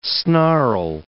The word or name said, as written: snarl